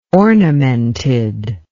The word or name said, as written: ornamented